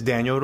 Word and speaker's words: Danial: Hi, my name is Daniel Ruiz. My Instagram handle is Denial Daniel, but it's spelled D-E-N-I-A-L and then D-A-N-I-A-L.